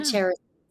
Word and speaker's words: charis: The Charis grace. – It's technically, I think the official pronunciation, it's a Greek word, and it means is charis, but it's connected with grace and love.